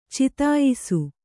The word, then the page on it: ಚಿತಾಯಿಸು: citāyisu